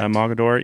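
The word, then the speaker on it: Mogadore: Like I just mentioned in the intro, we are here with Randy Wood from 10 Point Crossbows here in Mogador, Ohio. Am I saying that right? – Yeah, that is correct. – Mogador, yep.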